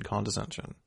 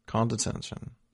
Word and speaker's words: condescension: Condescension. Am I saying it right? – I think so. – Condescension. – You keep adding an extra end in there. – Ah, dang. Condescension. – There we go.